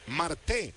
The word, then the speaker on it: Marte: Marté, él con acento en la última sílaba. Marté, a diferencia de Ketel Marte, dominicano también, de los diamantes.